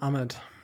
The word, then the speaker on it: Ahmed: Ich glaube, am besten sah noch der, entschuldigt mir, wenn ich ihn falsch ausspreche, der Ahmed aus. Wie wird er ausgesprochen? – Ja, Salvon Ahmed.